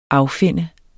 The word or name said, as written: affinde